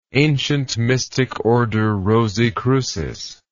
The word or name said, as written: Ancient Mystic Order Rosae Crucis